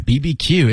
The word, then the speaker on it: BBQ: BBQ you can yeah BBQ it takes as long to say as barbecue because it's the same number of syllables so a lot of people say barbecue but you could say BBQ no problem